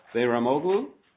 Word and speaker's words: Behramoglu: Mr. Behramoglu, did I come even close to the correct pronunciation? – Very well, yes.